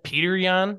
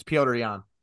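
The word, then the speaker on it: Petr Yan: Isn't it Peter Jan, not Piotr? – It's Piotr Jan.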